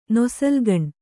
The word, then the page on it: ನೊಸಲ್ಗಣ್ಣ: kannada